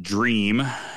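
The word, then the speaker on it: Jream: Tony, this beer was called Dream. It's spelled with a J, J-R-E-A-M.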